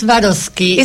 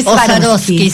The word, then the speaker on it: Swarovski: Es Swarovski. Es Swarovski. Se puede decir de las dos formas.